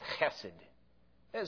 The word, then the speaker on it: chesed: It's pronounced chesed.